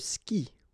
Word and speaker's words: skie: And then, je skie. Okay, exactly the same thing. Je skie. Okay, you get this I, and after that you get the vowel E, but then you don't pronounce it.